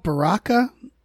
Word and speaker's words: Baraka: Baraka? Am I saying that right? – Yeah.